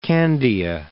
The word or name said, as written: Candia